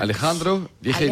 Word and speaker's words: Alejandro Vigil: Euh, Alejandro Viril, je ne sais pas si je le prononce bien. – Hein. Alejandro Vigil. Alejandro Vigil.